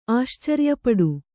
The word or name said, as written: ಆಶ್ಚರ್ಯಪಡು